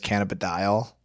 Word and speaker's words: cannabidiol: So I've looked up how to pronounce this word 5 times, and I can't remember what it is, but I'm pretty sure it's cannabidiol, mm-hmm.